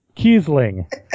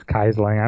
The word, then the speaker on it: Kiesling: Kiesling. He's such a god, I know how to say his name properly. – Excellent. – It's Kiesling. I'm totally going with that.